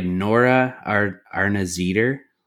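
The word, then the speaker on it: Nora Arnezeder: And Anna is played by Nora Arnazeder. I don't know if I'm pronouncing that right or not. – Nailed it, man. Nailed it.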